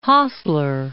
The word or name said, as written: Hassler